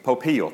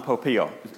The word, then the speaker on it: Popeil: Now, as we go through these next verses, 15, 16, 17, and 18, I want you to picture Ron Popeye. You know what I'm talking about? I think I'm saying his name right. Popeil. Thank you. I knew somebody would help me. Awesome. Ron Popeil.